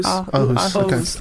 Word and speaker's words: Aarhus: Aarhus. Aarhus.